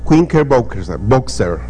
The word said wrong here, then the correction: Knickerbocker: ¿Cómo se dice? Knickerbocker.